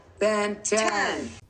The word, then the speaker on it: Benton: Make it two syllables. Benton.